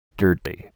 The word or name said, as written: dirty